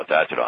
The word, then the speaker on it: Tatro: And so he changed his name from T-A-T-R-E-A-U-X, mm-hmm. T-A-T-R-O. And they said, how do you pronounce it? And he says, Tatro, Tatro, I'm Italian. And actually he was a French Canadian. So we say it's Tatro. Sounds like Italian,